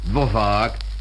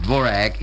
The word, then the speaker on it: Dvořák: One cannot listen very long on the radio without hearing the compositions of Antonín Dvořák, or is pronounced Dvorak in America, and those of Rudolf Frimer.